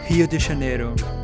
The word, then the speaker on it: Rio de Janeiro: Matt is a Y Combinator alumni, entrepreneur, pilot, and digital nomad, currently living and working in beautiful Rio de Janeiro. That's how the locals pronounce it.